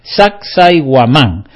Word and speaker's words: Sacsayhuamán: A mí me cuesta pronunciarlo un poco porque el Sac no es con C, es con Q, es S-A-Q, Sac. Sai con Y, Guamán, Sacsayhuamán. Bueno, así se puede pronunciar.